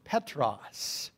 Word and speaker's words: Petros: The word Peter, in Greek, is pronounced Petros.